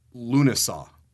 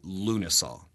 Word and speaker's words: Lughnasadh: It's called Lunasa, L-U-G-H-N-A-S-A-D-H, and I believe you say that Lunasa.